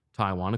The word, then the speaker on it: Tiawan: Now, Taiwan, spelled differently, T-I-A-W-A-N, but it is pronounced Taiwan, according to the Eagles bio.